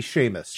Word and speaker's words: Seamus: Oh, S-E-A-M-U-S. That would be Seamus.